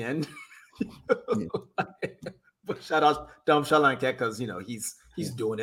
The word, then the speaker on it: Solanke: I'm glad that you said his name properly, because the way they'd be saying his name, Solanke. That is not how you say his name. Please stop that.